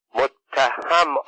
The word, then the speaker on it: متهم: متهم را با کسر ه تلفظ می کرد متهم دکتر مصدق به میان سخنانش دوید و گفت متهم آقا با فتح ه است